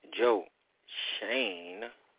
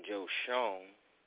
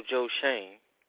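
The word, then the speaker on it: Schoen: I called him Joe Shone in previous episodes, didn't know how to pronounce his name correctly, but now that he is a New York Football Giant general manager, please put some respect on his name and get it right.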